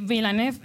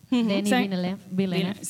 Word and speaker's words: Villeneuve: Que Villeneuve. – Denis Villeneuve.